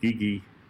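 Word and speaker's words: cici: And kiki, which is pronounced more like gigi, means cute in Turkish, which could give that word associations with sort of a round-headed chubby babies or something like that.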